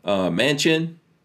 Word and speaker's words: Manchin: And he's showing Manchin.